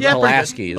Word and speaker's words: Pulaski: First time, let's get to know General Pulaski. – Mm-hmm. – Am I pronouncing that Polish name right? I think it's usually Pulaski.